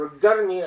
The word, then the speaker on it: al-Qarni: Salman al-Odah, Awad al-Qarni, or al-Qarni as the Saudis pronounce it, and Ali al-Omari, or al-Amri.